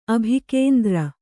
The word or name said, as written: ಅಭಿಕೇಂದ್ರ